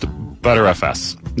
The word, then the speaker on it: Btrfs: I think it's pronounced ButterFS, not to be confused with Butterface.